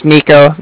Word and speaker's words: Niko: I said, my name's Nico. That's spelled N-I-K-O. And then the Bank of Cardiff is spelled C-A-R-D-I-F-F, as in Frank.